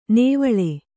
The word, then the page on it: ನೇವಳೆ: nēvaḷe